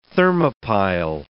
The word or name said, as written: thermopile